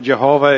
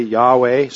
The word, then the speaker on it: Yahweh: So, you know that Jehovah is really not the right pronunciation for God's name. And so the scholars say Yahweh.